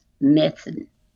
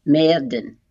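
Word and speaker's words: Myrddin: His name in Welsh is pronounced Methan, Merdan.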